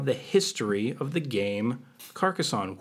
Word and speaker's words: Carcassonne: I believe the correct pronunciation. Carcassonne.